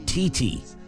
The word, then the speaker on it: Titi: In a segment called What's Wrong with These Signs, Ellen showed a sign advertising a real estate broker whose name is TT. Spelled T-I-T-I, but she says it's pronounced T-T, her last name Pierce. Ellen poked fun at the name for sounding like another word for breasts, but T-T says the comedian has it wrong.